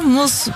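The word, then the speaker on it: mousse: Ha, mos. Mos diye okunuyormuş bu arada. Mos şey, böyle fare gibi duyuluyor şimdi. – En başından beri yanlış mı söylemişiz millete? – Fransızca mos. Yani.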